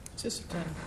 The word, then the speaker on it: Sisseton: Sisseton. – South Dakota. – Spell that her. – S-I-S-S-I-S-T-O-N.